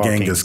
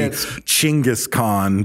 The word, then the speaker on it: Chinggis: Yeah, the meeting is called the Kurultai, and in this they dub him the universal leader, which translates King to Genghis Kings, Khan, which is actually Chinggis Khan, but we say Genghis because it's like it's spelled Genghis Khan.